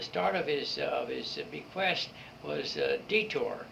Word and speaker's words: Detur: D-E-T-U-R, the Deter Prize. – Deter. – Deter, all right. – Bob, you didn't have Latin. You didn't know how to pronounce it. Deter.